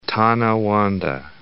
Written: North Tonawanda

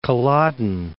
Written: Culloden Moor

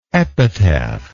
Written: epitaphless

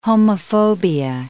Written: homophobia

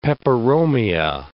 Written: peperomia

